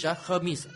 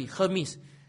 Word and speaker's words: Hermes: That is how it's pronounced in English, Hermes. I went to check. At Oxford Dictionary, Hermes. Okay, not the French one, English, H-E-R-M-E-S, not Greek, English, Oxford, according to Oxford Dictionary, Hermes, okay? H-E-R-M-I-Z, right, the way it is pronounced, Hermes.